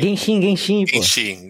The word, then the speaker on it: Genshin Impact: E aí o outro impactante, que é o Genshin Impact, que chama a pronúncia, não sei. – Genshin Impact.